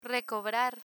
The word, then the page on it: recobrar: Spanish